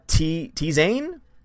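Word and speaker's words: Zehn: I feel like I should probably tell you it's pronounced uh, T Zane? It's German for 10.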